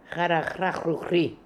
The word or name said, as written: Harahrahroohry